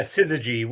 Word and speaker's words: Syzygy: It's Syzygy. – The good thing is it's whatever people want it to be. That's what it is. – Syzygy, right? – Yeah, Syzygy.